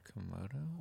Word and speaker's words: Komodo: I just can't stop thinking about Kimoto. Kimoto dragons? Is that how you say it? – Komodo? – Wearing a kimono.